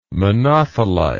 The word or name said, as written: monothelite